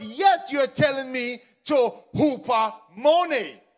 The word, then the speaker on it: hupomone: And the Greek for this word is hupomone. Hupomone. It's spelled H-U-P-O, but it's pronounced as in a basketball hoop.